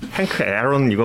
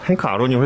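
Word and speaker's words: Hank Aaron: 예, 처음에 이제 헨크 아론이라고 어린 시절부터 알고 있어서 헨크 에런 이거보다 헨크 아론이 훨씬 좀 입에 좀 닿는 발음인데 아 너무 슬프더라고요 사실 예.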